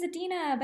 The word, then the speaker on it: Adena: It's when people are always like, oh, your name's Adena, but it's not spelled with an I. I'm like, no, because it's not Idina. It's kind of spelled the way it sounds.